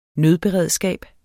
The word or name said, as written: nødberedskab